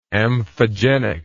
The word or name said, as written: amphogeny